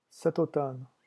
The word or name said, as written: cet automne